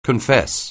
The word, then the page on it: confess: anglais